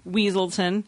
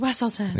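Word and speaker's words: Weselton: Weaselton. – Mm-hmm. – It's pronounced Wesselton.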